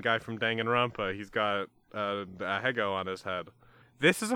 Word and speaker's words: ahegao: How do you pronounce that? Ahego? – I think you got it.